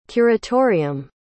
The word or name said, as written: curatorium